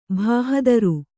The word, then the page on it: ಭಾಹದರು: kannada